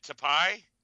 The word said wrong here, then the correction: Taipei: Taipei. – Taipei.